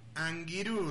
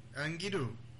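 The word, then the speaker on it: angirũ: Será por eso que amigo en guaraní tiene una especial simbología en su pronunciación, anguirú, dicen, anguirú, que es una palabra compuesta por anga, que significa alma, y por irú, que significa compañero.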